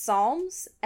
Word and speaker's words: Psalms: And the second thing is, it's pronounced Psalms and not Psalms, which is how Chaboy pronounced it for way too long.